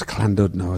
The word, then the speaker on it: Llandudno: In Llandudno in Wales, Llandudno as it's pronounced.